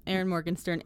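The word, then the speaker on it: Erin Morgenstern: Yep, Aaron Morgenstern, and it is an audiobook.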